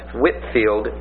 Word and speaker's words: Whitefield: Now, Whitfield is spelled like Whitefield, W-H-I-T-E-F-I-E-L-D, but it's pronounced Whitfield, even though there's an E in it.